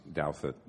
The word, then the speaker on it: Douthat: But I think you're right that, in fact, I think Ross Douthat, am I saying his name right? – Yeah.